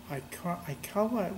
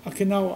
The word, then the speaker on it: Okinawa: And they end up in the island, I don't know where, Aikawa, whatever it is. – Okinawa.